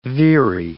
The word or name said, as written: veery